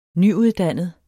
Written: nyuddannet

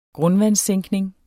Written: grundvandssænkning

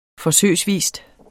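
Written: forsøgsvis

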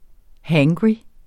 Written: hangry

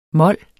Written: mol

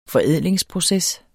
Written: forædlingsproces

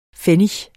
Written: pfennig

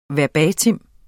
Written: verbatim

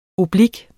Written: oblik

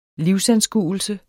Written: livsanskuelse

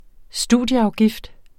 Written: studieafgift

